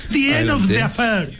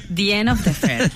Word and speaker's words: The End of the Affair: The end of the affair. – The end of the affair, claro.